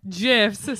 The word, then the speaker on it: GIF: Also, the man that invented Jifs says that's how you pronounce it.